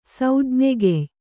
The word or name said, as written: ಸೌಜ್ಞೆಗೆಯ್